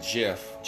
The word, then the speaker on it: GIF: And lo and behold, when we thought she couldn't, the gift that keeps on giving, maybe the GIF, G-I-F, GIF, is it GIF or JIF? Tim Pool pronounces it JIF, G-I-F. I pronounce it GIF, but what the hell ever.